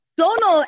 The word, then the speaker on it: Sonal: Sonal actually rhymes with Donal, which is an Irish name.